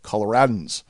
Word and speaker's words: Coloradans: Yes, that's how you say it, Coloradans.